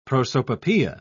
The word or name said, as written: prosopopoeia